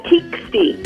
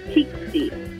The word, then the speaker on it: Teksti: Did you say Tixty? – Yeah, Tixty. – Tixty TV. – T-E-K-S-T-I. – Right. – But I actually looked it up, and it's pronounced Tixty.